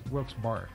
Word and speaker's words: Wilkes-Barre: Lancaster, Malvern, Wilkes-Barre, is that how you pronounce it? – Or yeah. – Wilkes-Barre. Wilkes-Barre, okay.